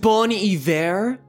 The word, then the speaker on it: Bon Iver: Um, it's pronounced Bon Iver.